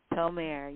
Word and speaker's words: Tolmarie: Tolmere. Tolmere, yes. Thank you. I heard you, and then it's like I didn't put a phonetic pronunciation there. But thank you for the correction.